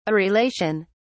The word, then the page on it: areolation: British English